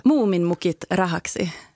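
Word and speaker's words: Muumimukit Rahaksi: Vad säger man? Hur säger man det? – Muminmukit Rahaksi. – Där har vi det.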